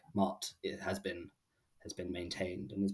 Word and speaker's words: Maat: But what this feather actually represents is an Egyptian goddess called Maat, which is spelled M-A and then a little stop and then A-T.